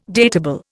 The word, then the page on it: datable: Indian